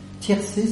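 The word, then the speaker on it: Thiercé: Tiercé, ça s'écrit avec un H, T-H-I-E-R-C-E.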